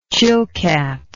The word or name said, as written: Chilkat